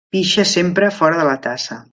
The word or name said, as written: fora